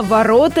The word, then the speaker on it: ворота: Так что ставить ударение на последний слог в слове «ворота» неправильно. Правильнее в повседневной жизни говорить именно «ворота» и никак иначе.